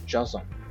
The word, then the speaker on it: Joseon: Então, por exemplo, o fim do Goryeo e o começo de Joseon, falei certo, né? – Joseon.